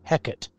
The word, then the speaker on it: Hecate: Hecate is how to pronounce it. – Hecate. – I always said Hecate or Hecate. But yeah, I guess it's Hecate.